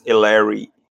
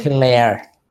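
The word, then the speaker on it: Helaire: O running back Edwards Ellery. É Ellery que pronuncia? É, né? Hilaire.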